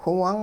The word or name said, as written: whiwung